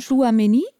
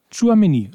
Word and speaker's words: Chouameni: Übrigens, ähm, ähm, weil du von dir aus sozusagen auf äh, Tucham, oh, da muss ich aber noch üben, wie, bis ich den ausgesprochen du meinst habe. Chouameni? – Chouameni, ist doch so einfach, ja.